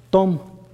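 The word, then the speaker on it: Tom (תום): Tom, T-O-M se diría en español y se pronuncia así.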